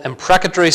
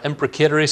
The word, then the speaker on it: imprecatory: These are what are sometimes called imprecatory psalms or imprecatory psalms, depending on which pronunciation you prefer.